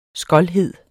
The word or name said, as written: skoldhed